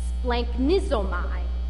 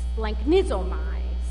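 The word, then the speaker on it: splanknizomai (σπλαγχνίζομαι): So the Greek translation is pronounced, this is a mouthful, splanknizomai. Got it? Splanknizomai.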